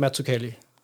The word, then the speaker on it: Mazzucchelli: Hvis du skal være udtalt på italiensk, er det Mazzucchelli.